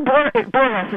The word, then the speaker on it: Borges: And I heard Borges is how his name is pronounced.